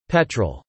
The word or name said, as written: petrol